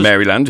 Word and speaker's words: Maryland: Maryland, yep.